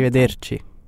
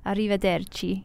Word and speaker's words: Arrivederci: Arrivederci. – Arrivederci. – Arrivederci. – Good. You can roll your R's.